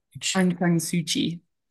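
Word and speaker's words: Aung San Suu Kyi: And yeah, I'm going to butcher the name. Ansang Shi, is that right? – Ansang San Suu Kyi. – Suu Kyi, yeah. yeah. So it's like KYI, isn't it? – Yeah.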